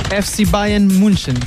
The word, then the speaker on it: Bayern München: FC Bayern München.